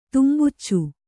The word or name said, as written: ತುಂಬುಚ್ಚು